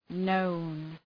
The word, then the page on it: known: nəʋn